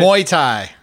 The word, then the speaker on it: Muay Thai: Muay Thai.